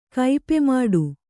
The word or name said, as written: ಕೈಪೆಮಾಡು